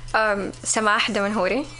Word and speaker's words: Sama Damanhuri: Could you say it for Arabic version? – Yeah. – Sama Damanhuri.